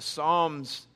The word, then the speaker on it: psalm: But the Psalms is very influential in the New Testament. Jesus quotes the Psalms. Now, what is a psalm? P-S-A-L-M, right? Silent P, psalm.